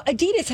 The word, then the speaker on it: Adidas: But it's a last name. And the last name is pronounced Adidas.